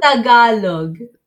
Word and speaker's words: Tagalog: It's TELAGDU. – No. Yeah. – Not even close? – It's Tagalog. – Tagalog. Tagalog. – Okay, Tagalog. So a white person would pronounce it Tagalog. – No, I'm not going to say that. I can't, no. – But in the Philippines, the vowels are very blunt, so it's Tagalog.